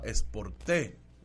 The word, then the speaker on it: Sporté: Como dice el americano, se llama Sporté. Así se está pronunciando correctamente.